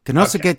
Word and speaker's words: Konosuke: Konosuke Takeshita. So, Konosuke, but you pronounce it Tanosuke. You want to say it smooth like that. You don't want to say it choppy.